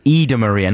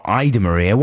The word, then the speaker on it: Ida Maria: That was Ida Maria, not Ida Maria. Why would you pronounce it Ida Maria? – I didn't, you did.